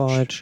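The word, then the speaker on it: Spajić: Wie spricht man den genau aus? Ich glaube schon Spajic. Spajic, ja.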